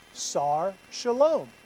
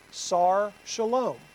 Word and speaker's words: Sar Shalom: And in the Hebrew, that word or that phrase is Sar Shalom. So you'll see this. It is Sar Shalom. Right? That's how you say it.